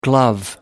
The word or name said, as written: glove